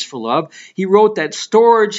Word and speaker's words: storge: The first one, though, is pronounced storge. It's spelled S-T-O-R-G-E.